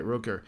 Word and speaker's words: Rutger: Also, to be honest, he gave Rutger gave his name is Rutger, isn't it? Am I saying this wrong all the time? – No. – Okay, Rutger.